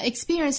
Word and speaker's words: experience: 那 experience 这个词我们需要注意的呢，就是这个字母 p，这里其实是发成一个类似于 b 的发音，不能发成 p。所以这个词不发 experience，而我们听到多数时候它是被发成 experience。